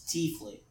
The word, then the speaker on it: tiefling: I've always known it as T. T-E-A. Fling. – Tefling sounds too much like Teflon. So I believe it's T-Fling. – Yeah, I've always pronounced it T-Fling.